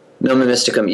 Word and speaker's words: Nomen Mysticum: if I'm pronouncing it right, your Nomen Mysticum name? – Nomen Mysticum, yes.